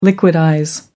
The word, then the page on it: liquidize: American English